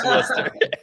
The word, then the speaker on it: Worcester: They played the two in Worcester, or however one would pronounce that correctly. Worcester. – Yeah, no, we've hammered that part down now. We know it's Worcester.